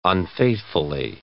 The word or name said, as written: unfaithfully